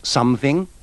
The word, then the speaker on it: something: In these sentences, remember to pronounce the O in something, somebody, and someone with the sound of the U in cut.